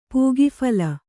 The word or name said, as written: ಪೂಗಫಲ